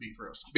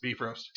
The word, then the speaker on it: Bifrost: And it is pronounced Bifrost. – Bifrost? – Bifrost.